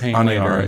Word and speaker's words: Aniara: Aniara. Aniara? – Aniara? – Aniara? I think it's Aniara. – Yeah. – I believe it's Aniara.